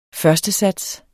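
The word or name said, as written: FØRSTESATS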